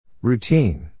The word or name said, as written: routine